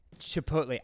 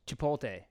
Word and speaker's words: Chipotle: We'll see how many ways we can say Chipotle. I say it wrong, I guess. I say Chipotle, and it's not that. – It's Chipotle.